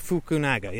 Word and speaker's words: Fukunaga: I think was it Cary Fukunaga? – Fukunawa, I never get his name right. The guy that brought us True Detective season one, all the episodes, you know, just. Cary Joji Fukunaga, yeah.